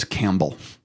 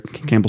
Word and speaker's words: Campbell: My last name is Campbell. How do you would you say Campbell? Is that fair? Campbell soup called Campbell, right? – Don't say the P, no.